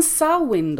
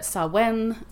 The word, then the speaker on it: Samhain: Eh, nej men Sawin då, det uttalas Sa-win, som att vinna någonting har jag precis fått reda på av mina irländska vänner. Inte Sawen eller någonting sånt, utan det är Sawin.